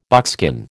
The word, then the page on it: buckskin: American English